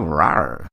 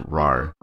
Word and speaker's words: Rahr: She incorrectly accented Rahr. – Rahr. Oh. – She goes, oh, Rahr. – Like roar, like ram. – It's spelled R-A-H-R. That is a fucking terrible joke. So it's Rahr's Blonde.